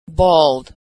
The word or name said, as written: bald